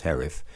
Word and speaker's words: Haruf: He said, have you ever read Kent Herif, spelled H-A-R-U-F, but pronounced Herif, particularly a novel called Plainsong?